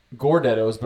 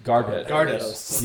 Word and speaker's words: Gardettos: It is the Gordettos, but I'm pretty sure they're pronounced Gardettos.